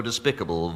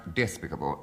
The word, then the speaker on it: despicable: My faults are so despicable, vile, low, that only... – Kenneth Williams. – Deviation. The root is despite, therefore it's pronounced despicable. No, you don't accent the I.